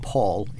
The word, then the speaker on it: Paal: I told you my name is spelled P-A-A-L, Paul, but it's pronounced Paul. It's a Danish version of the O sound because we were under Denmark for 400 years in a union where we were colonized.